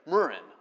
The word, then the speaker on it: murrain: The emphasis is on the first syllable. Anyways, murrain, all right?